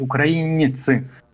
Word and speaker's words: украинцы: То же самое по поводу украинцев – а, я украинцев, я должен произносить это на, на мягкий манер. Я говорил перед началом передачи, пожалуйста, произносите правильно по-украински украинцы. А, Если во, во французском языке миньон, а, вы должны мягко произносить не, не. Вот, миньон украинцы.